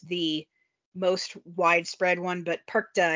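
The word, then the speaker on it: Perchta: Yes, that brings us to Frau, I'm gonna mispronounce this, my German is terrible, Frau Perkta. – Close enough.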